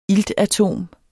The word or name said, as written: ILTATOM